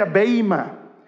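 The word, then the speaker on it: beima (βῆμα): Ahora, lo interesante es que la palabra, la palabra griega que está utilizando aquí Pablo es una palabra que se pronuncia beima.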